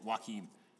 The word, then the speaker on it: Joaquin: Joaquin. There's a J in there. It confuses me. – Just spell it W-A-L-K.